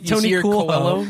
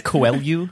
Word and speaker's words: Coelho: Tony Coolho. – If it's Paolo's brother, it's Coelho.